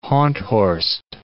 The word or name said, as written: Honthorst